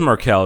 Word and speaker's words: Merkel: It is Merkel. It's pronounced Angela Merkel.